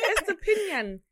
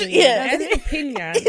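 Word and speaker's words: Estupiñán: Estupinyanyam. – Estupinyanyam. – Estupinyan. – Yeah, finish. – Estupinyan. That's how you pronounce Estupinyan.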